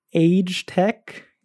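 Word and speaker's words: Agetec: Same thing with Age Tech, as I am now informed it is pronounced.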